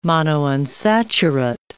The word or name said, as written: monounsaturate